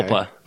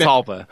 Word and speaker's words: Jalpa: It's J-A-L-P-A. – Oh, okay. – Halpa. – Okay. – I didn't know that's how you pronounce that.